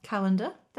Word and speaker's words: Callander: The place is called Callander. – It is. – Spelled like Callander. – No. Nope. Oh, spelled differently. – Well, there you go. – Yeah. Pronounced. – Carry on. – Pronounced the same.